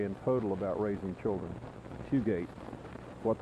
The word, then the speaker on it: Fugate: But it's what the Bible says about raising children, and the author is Fugate, F-U-G-A-T-E, I think it's the way it's pronounced.